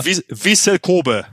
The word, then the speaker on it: Vissel Kobe: Vissel Kobe.